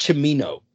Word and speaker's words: Cimino: Oh, so it's pronounced Camino. I just had to look. I feel like I thought I heard it say that before, but I wanted to make sure. Yeah, Michael Cimino.